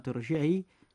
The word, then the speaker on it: ارجعي: وكلمة ارجعي أيضا الراء في كلمة ارجعي تنطق مفخمة مثلها مثل الكلمات السابقة.